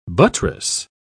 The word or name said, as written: buttress